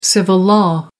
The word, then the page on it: civil-law: American English